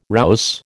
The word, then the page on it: rouse: American English